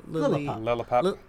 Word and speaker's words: Lillipup: At the very end of the spotlight hour, I got myself a little, how do you say that Pokemon? Like Lillipup or something? – Lillipup.